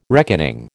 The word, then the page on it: reckoning: American English